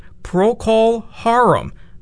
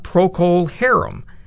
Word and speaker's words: Procol Harum: And according to the band, that is the way to pronounce it. Procol Harum, not Procol Harum.